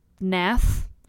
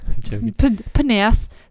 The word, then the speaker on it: Pnath: I mean, the name itself, The Veil of Nath, I'm assuming the P is silent, P-N-A-T-H, The Veil of Nath. Panath.